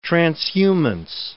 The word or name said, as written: transhumance